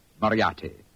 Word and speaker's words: Moriarty: I'm afraid I'll have to begin calling it the way it looks. M-O-R-I-A-R-T-Y. Moriarty.